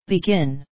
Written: begin

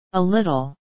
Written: a little

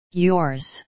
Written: yours

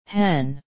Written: hen

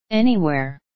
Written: anywhere

